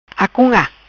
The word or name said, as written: Acunga